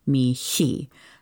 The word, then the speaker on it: mihi: Now, according to Bruce Sullivan's Layman Primer on Liturgical Latin, or at least according to my friend Lori's summary of that book, the letter H would be silent when sung. However, stylistically, at our parish, we tend to pronounce the H as though it were like a letter K, Mihi.